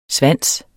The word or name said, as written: svanse